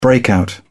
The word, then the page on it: breakout: British English